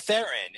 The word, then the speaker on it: Theron: Is it Theron or were you pronouncing it? – Well, it's Theron. It's Charlize Theron. – Okay. – So we thought it was Charlize Theron. And, like, I thought it was Charlize Theron my entire life pretty much because I'm a dum-dum.